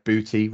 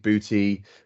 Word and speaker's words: Buhti: I'm going to pronounce this Buhti. So it's Bravo Uniform Hotel Tango India, Buhti ransomware. I think that's the way you pronounce it.